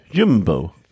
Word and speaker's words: Jumbo: Der meinte so, man musst du aussprechen, U wie Ü. Jumbo.